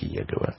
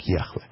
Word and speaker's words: Яхве: Имя Бога никогда не произносилось как Иегова. Сейчас специалисты считают, что, по-видимому, оно произносилось как Яхве в еврейском языке.